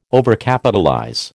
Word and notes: Overcapitalize: American English

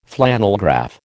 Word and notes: flannelgraph: American English

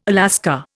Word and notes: Alaska: Indian

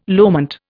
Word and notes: loment: Indian

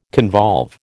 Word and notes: convolve: American English

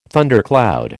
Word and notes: thundercloud: American English

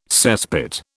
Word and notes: cesspit: British English

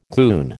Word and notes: goon: American English

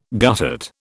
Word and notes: guttate: British English

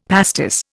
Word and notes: pastis: Indian

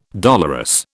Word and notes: dolorous: British English

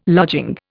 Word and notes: lodging: Indian

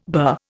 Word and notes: burr: Indian

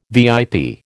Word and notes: VIP: American English

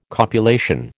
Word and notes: copulation: American English

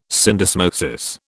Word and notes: syndesmosis: British English

